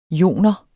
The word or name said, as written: joner